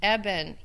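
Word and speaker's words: eben (אבן): Then you get this really, really neat play on words in here, because the Hebrew word for stone is pronounced eben, E-B-E-N. That's the word for stone. The word to build is bana, with like B-N-A, bana.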